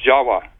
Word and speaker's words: Jawa: T-I-W. These are pronounced as W's in Dravida, such as in Java. The word in Java, the island of Java, you know, is pronounced Jawa, actually. Indonesians will pronounce it Jawa, not Java like we do.